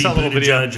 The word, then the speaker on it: Buttigieg: You can either be Buttigieg or Buttigieg.